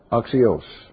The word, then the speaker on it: axios: Now, I can tell you the Greek word is axios, the long O.